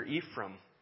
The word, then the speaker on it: Ephraim: Ephraim, we pronounce it here Ephraim.